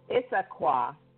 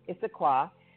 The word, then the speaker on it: Issaquah: Oh, it's very easy. It's Issaquah. Issaquah, which is a suburb of Seattle.